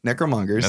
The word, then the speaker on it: Necromonger: And then the Necromonger show up and they take Kyra. Did I say it right? Necromongers.